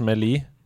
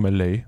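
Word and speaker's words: Melee: Men jeg sa, jeg sa jo Supersmash Smash med Lee hele mitt liv. Helt til noen sa til meg det er faktisk meløy. Det er sånn det uttales.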